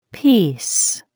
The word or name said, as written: piece